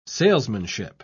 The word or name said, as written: salesmanship